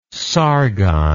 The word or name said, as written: Sargon